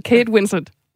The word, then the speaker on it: Kate Winslet: Øhm, nej, altså, det, det den ser kan der hvor den to røven på mig, var i virkeligheden, da Kate Winslet, eller Winslet, eller, nu kan ikke udtale hendes efternavn. – Jeg tænd slukker lige for mig selv. Kate Winslet.